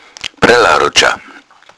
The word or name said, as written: brellaruddscha